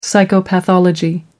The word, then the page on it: psychopathology: American English